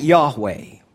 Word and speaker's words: Yahweh: In the Hebrew, that I am consists of four consonants: YHWH. We pronounce that Yahweh. In 1518, Petrus Galenus, a Catholic priest, mistranslated those four consonants, and he translated them as Jehovah.